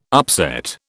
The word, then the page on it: upset: British English